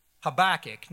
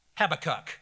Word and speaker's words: Habakkuk: And you might want to pronounce that Habakkuk, not Habakkuk.